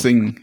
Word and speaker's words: Xing: Xing wird die, glaube ich, ausgesprochen. Sing, ja.